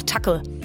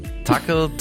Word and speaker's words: Tuchel: Ne, meine erste Version war auch Tuckel. – Tuchel, Tuchel.